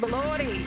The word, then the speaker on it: Elordi: I don't know how to pronounce his last name. Elordi? – Jacob Elordi. Brisbane's own Jacob Elordi is how we like to say it.